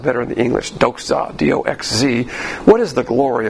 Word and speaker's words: doxa: D-O-X-Z-A when you pronounce it, but in the Greek, it's D-O-X-A, but you notice, for you purists out there, you notice that I throw a Z in there every once in a while.